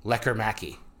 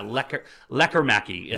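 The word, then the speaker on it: Lekkerimäki: I thought it was Lekker Mäki. – Yeah, so... – And the entire time they've been saying Lekkermäki. I'm like, you're wrong, man. Say it right.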